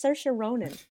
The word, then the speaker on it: Saoirse Ronan: It's Saoirse Ronan.